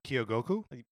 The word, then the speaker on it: Kyogoku: How would you say that? – Kyogoku? – Perfect.